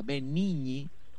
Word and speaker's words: Benigni: Dice en el italiano primero, así como escuchábamos recién, bueno, Roberto Benigni, así se pronuncia, Benigni, difícil de pronunciarlo.